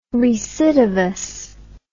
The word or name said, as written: recidivous